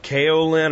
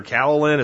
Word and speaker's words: kaolin: It's pronounced like kaolin or kaolin.